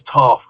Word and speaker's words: Toph: It was like, my name's Toph because it rhymes with tough.